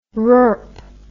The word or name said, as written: rurp